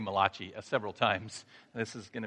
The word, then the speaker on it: Malachi: So today we're in the final book of the Old Testament, written by the only Italian prophet, Malachi. Just kidding. It's pronounced Malachi, okay?